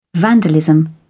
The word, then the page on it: vandalism: British English